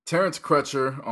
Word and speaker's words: Terence Crutcher: Terrence Crutcher on Friday night.